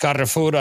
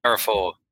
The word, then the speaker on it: Carrefour: And due to the fact that France has a huge chain that's all over Europe, which is, anyway, in Spanish, it's pronounced Carrefour. I don't know how they pronounce it in the UK or anywhere else. But yeah, Carrefour. Yeah, Carrefour. That's right.